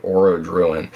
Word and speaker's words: Orodruin: I think of it as like Orodruin. But, you know, but... – That's probably it, based on pronunciation I've heard for other things in the Tolkien universe, so...